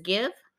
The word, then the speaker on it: GXVE: She has some, it's called GXVE, and as you pronounce it, it's Give. – GXVE. – Yes, like Give.